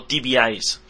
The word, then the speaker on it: TVEyes: O TVEyes sería la o sea, la pronunciación correcta.